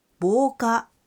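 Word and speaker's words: বোকা (防火): এই কুইজের দ্বিতীয় অর্থাৎ নীল কাঞ্জির উচ্চারণ হচ্ছে বোকা, বোকা।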